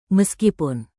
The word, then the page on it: meskipun: Indonesian